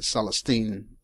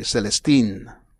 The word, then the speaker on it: Celestin: And, you know, I'm pronouncing it the French way, but it's like C-E-L-E-S-T-I-N, Celestine, Celestin. Celestine would be like the female. Mm-hmm. Celestin would be the male, but they say Celestin.